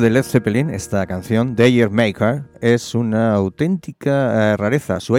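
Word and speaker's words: D'yer Mak'er: El título es incluso es un guiño a ese ritmo, porque Dayer Maker se pronuncia de forma muy parecida a Jamaica.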